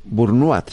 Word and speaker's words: burnout: Bueno, pues Burnout se pronuncia, ¿no? Entiendo que es una palabra francesa. – No, no, es inglesa.